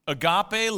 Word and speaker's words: agape: Everybody say escape. Now everybody say agape. And now you'll remember it. Agape. What is agape love? Turn to your neighbor, say agape. Finding Nemo. Anyway, defined, agape love is perfect, unconditional, sacrificial, and pure.